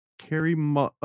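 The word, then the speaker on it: Kary Mullis: Carrie Mullisk, dude, can you pull up a picture of Carrie Mullisk, like out of images? – Is this a person? I'm not familiar with the name. – Oh, I'm sorry, I said it wrong. Kerrymullisus.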